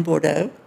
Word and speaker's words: Bordeaux: Yes, we spent one weekend in Bordeaux.